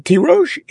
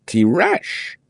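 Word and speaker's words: tirosh (תירוש): But tirosh is written without a vav. And that way it should be read tirash, but it isn't.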